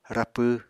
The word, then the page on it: rapw: Apatani